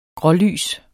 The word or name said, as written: grålys